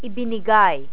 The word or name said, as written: ibinigay